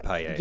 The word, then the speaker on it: paella: Am I saying it right? – Yeah, the paella.